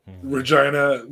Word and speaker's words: Regina: Regina rhymes with vagina.